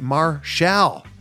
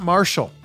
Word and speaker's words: Marshall: And yes, as he tells us in this interview, that's how it's pronounced. It is QT Marshall, not Marshall.